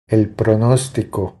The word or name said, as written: El pronostico